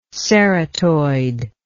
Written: ceratoid

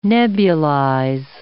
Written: nebulize

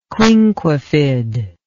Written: quinquefid

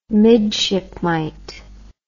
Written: midshipmite

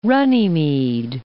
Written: Runnymede

